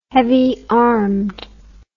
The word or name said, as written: heavy armed